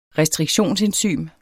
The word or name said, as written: restriktionsenzym